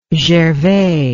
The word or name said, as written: gervais